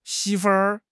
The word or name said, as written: 媳妇